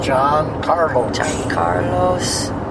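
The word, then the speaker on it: Giancarlo: John Carlos.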